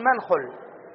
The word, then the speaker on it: منخل: وأيضا منخل، منخل آلة النقل، هذه نبهنا عليها في المقدمة، منخل نحن نقول منخل، وقد تراها فتظنها على منخل كمصعد ومشرط ومبرد ونحو ذلك، لا، منخل شذ هكذا، ومسعط يعني الذي يستعمل له يستعمل فيه الدواء عن طريق الأنف مسعط.